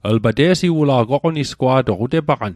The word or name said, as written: oqonisgwa'toq